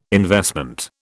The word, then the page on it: investment: British English